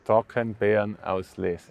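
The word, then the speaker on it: Trockenbeerenauslese: How do you say it in German? In Germany, it's Trockenbiernaulese. – Yeah, exactly.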